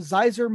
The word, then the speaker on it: Zaiser: I'm here with a special guest today, Anthony Cross, the co-founder, CEO of Zaiser Motors. Am I saying that right? – Nailed it.